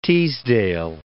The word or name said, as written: Teasdale